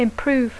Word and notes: improve: English

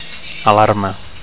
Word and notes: alarma: Catalan

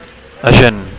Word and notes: agent: Catalan

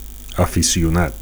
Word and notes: aficionat: Catalan

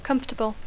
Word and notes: comfortable: English

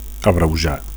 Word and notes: abreujar: Catalan

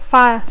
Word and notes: fire: English

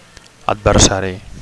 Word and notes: adversari: Catalan

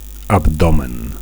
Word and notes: abdomen: Catalan

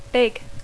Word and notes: big: English

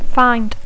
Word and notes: find: English

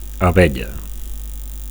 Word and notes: abella: Catalan

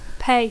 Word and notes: pay: English

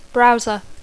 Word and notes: browser: English

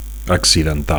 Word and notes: accidental: Catalan

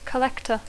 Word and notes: collector: English

